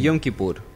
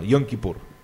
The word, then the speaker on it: Yom Kippur: Me lo habías dicho también en Yom Kippur. – Así se llama, Yom Kippur.